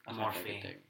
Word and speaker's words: Morphe: Yeah, his one with Morph is really cool. – Morphe. – It's literally Morphe. – Well, it's just M-O-R.